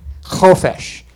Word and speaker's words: chofesh: It's got the ch in the beginning, chofesh. And this is the definition. Freedom from external restraints. Freedom to do your own will. To help remember chofesh, it rhymes with flesh.